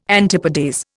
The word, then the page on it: antipodes: Indian